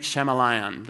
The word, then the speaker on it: Shyamalan: Night Shyamalan.